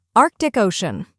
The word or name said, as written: Arctic Ocean